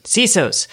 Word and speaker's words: CISOs: I know we have CISOs? Is that how you say it? CISOs? – Right. CISOs.